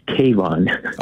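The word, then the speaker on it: Kavon: It's Kayvon. – Oh, thank you for taking my call. – How do you spell that? Okay. And I'll fix this. – No, it's K-A-V-O-N, like Avon with a K.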